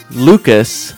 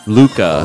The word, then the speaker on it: Lucas: Lucas in French, obviously, does not get the pronounced S. Luca. Oh. So Luca is fine.